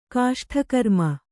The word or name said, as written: ಕಾಷ್ಠಕರ್ಮ